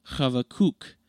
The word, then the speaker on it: Habakkuk: The Hebrew name is pronounced Habakkuk, in which the last syllable is emphasized.